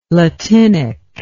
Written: latinic

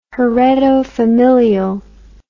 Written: heredofamilial

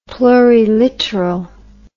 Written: pluriliteral

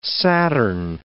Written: Saturn

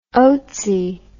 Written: oatsy

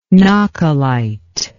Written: nahcolite

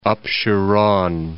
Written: Apsheron

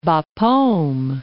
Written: Bapaume

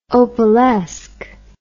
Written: opalesque

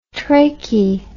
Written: trachy

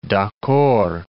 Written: d'accord

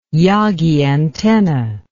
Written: Yagi antenna

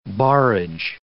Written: barrage

